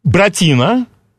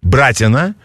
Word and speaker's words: Братина: А у нас сейчас, вот я нашел, казачий ансамбль «Братина». По-моему, я правильно поставил ударение. Неправильно. «Братина»? «Братина».